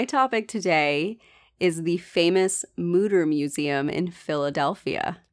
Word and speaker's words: Mütter: It's supposed to be like pronounced with an E-O sound, but they say we're happy if you say Mütter like scooter.